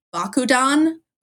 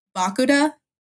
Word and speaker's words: Bakuda: So it's that emphasis on the ba. – Yeah. – And then kuda. Yeah, exactly. So Bakuda.